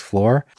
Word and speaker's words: Shloer: And it's funny, it's spelled S-H-L-O-E-R, but it's pronounced floor.